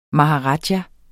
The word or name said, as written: maharaja